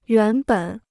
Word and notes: 原本: yuán běn

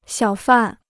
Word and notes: 小贩: xiǎo fàn

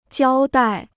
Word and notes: 交代: jiāo dài

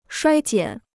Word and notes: 衰减: shuāi jiǎn

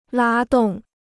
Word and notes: 拉动: lā dòng

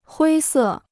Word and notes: 灰色: huī sè

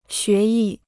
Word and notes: 学艺: xué yì

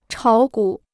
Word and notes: 炒股: chǎo gǔ

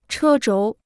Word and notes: 车轴: chē zhóu

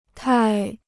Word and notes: 太: tài

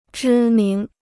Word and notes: 知名: zhī míng

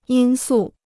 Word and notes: 因素: yīn sù

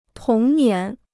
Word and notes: 童年: tóng nián

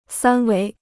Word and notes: 三维: sān wéi

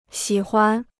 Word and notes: 喜欢: xǐ huan

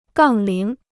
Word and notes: 杠铃: gàng líng

